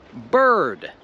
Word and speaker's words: bird: I w słowu bird, to i, albo w polsku i, się wymawia tak jak ten I w słowu girl.